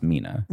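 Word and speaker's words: Minna: And the person being interviewed primarily is Rabbi, I'm guessing her first name is pronounced Mina. – Yes. – It's M-I-N-N-A.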